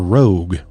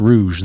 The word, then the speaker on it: Nain Rouge: I don't know if it's Rouge or Rogue, right? I think it's Rouge. The Nain Rouge is, I believe, how it's supposed to be pronounced.